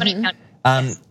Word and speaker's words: Gwinnett: So you mentioned on your campaign website that Gwinnett County, is that how you pronounce it? – Gwinnett County.